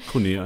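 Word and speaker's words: Koné: Cuyadio Kone, ich hoffe, ich habe es richtig ausgesprochen. – Kone einfach.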